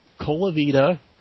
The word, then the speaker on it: Colavita: Colavita, C-O-L-A-V as in Victor, mm-hmm. I-T-A, mm-hmm.